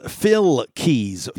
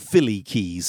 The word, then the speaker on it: PhilKeyz: It's Phil Keys. He's not Philly Keys.